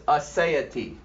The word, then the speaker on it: aseity: First incommunicable attribute is aseity. That is spelled A-S-E-I-T-Y.